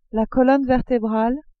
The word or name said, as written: colonne vertebrale